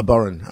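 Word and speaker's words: bodhrán: That's a fiddle, you know, and a burren, which is an Irish. – What's it called? – A burren. I think it's spelled Bodran, Bodran, but a bit of burren.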